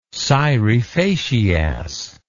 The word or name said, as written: scire facias